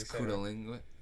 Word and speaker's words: cunnilingus: If you do cunning linguist, and they do what? – Cunning linguist. – You're not saying it right. – Cunning linguist. – You're not saying it. – It's cunnilingus. – Cunnilingus.